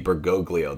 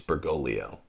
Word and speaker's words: Bergoglio: People pronounce that second G, Bergoglio, that's wrong. It's Bergoglio. You don't say Lasagna unless you're joking. It's Lasagna, right? Bergoglio, it's an Italian name.